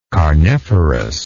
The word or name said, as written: carniferous